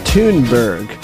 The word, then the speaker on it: Thunberg: I do think it's Thunberg in the Age of Silent.